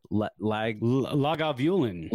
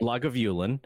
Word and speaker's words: Lagavulin: Lagavulin. – Lagavulin.